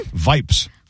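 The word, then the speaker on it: Yipes: Vipes. Rhymes with stripe, I guess.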